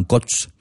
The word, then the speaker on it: Cos: La paroisse de Saint-Amand de Côte, a pris le nom d'un lieu-dit voisin situé sur la commune de Caylus, Cos, prononcé localement Côte, et qui peut être une variante de Cas, puisqu'en Quercy, le O est volontiers prononcé A.